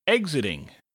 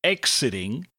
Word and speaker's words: exiting: Our next word is exiting. Now, usually an X sounds like a K and an S together, X. But in this case, it sounds more like a G and Z together. So instead of exiting, it's exiting. Exiting.